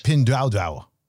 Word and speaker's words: Pinduoduo: It's Pinduoduo.